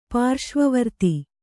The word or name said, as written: ಪಾರ್ಶ್ವವರ್ತಿ